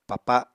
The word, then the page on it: papa: Apatani